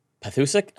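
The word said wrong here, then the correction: Pethokoukas: I grew up with a lot of Greeks. Not a lot, but a few. It's Pethikoukas.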